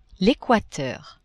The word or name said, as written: equateur